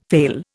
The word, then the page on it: fail: Indian